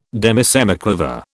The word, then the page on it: demisemiquaver: British English